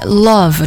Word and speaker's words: love: 接下来 love 这个单词也是非常非常常见的 I love you 我爱你的意思对吧，但是很多同学会读成 love 啊，注意里面的那个字母 o 发一个小口的 a 的音，你不要用中文的 a 去替代，开口不要过大，然后那个字母 v 它发 v 的音啊，不是中文的我啊，下嘴唇要接触上牙齿底端去发这个 v love 不要读成 love 啊。